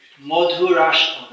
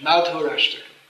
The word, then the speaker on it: Madhurashtakam: Through the words of another Acharya, Vallabhacharya, we can sing this Madhurashtakam, that's Bengali pronunciation. Madhurashtakam.